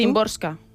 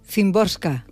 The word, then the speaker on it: Szymborska: A ver, ¿cómo lo pronuncias tú? – Szymborska. – Szymborska. – Entiendo yo que sí, por lo como yo he escuchado a la gente pronunciarlo.